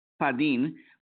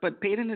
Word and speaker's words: Padin: It's actually Spanish. – It's Spanish. – It's Padin. But Padin is fine.